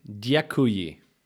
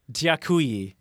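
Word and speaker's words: дякую: Yakuye. – It's with a D. Dyakuye. – Oh, right. Dyakuye. – There you go. That was pretty good. Uh-huh. – And Dyakuye is obviously we thank you.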